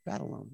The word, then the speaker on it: Batalon: I think, you know, the core trio, you know, Tom Holland, Zendaya, Jacob Batalon, is that how you pronounce that? Batalon, yeah.